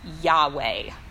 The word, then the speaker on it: Yahweh: In English, we pronounce that word as Yahweh, and we consider it to be God's proper name. Whenever you see the Lord, L-O-R-D, in small capital letters in your Bible, In the original language, that's the proper name of God, Yahweh.